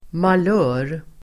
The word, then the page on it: malör: [mal'ö:r]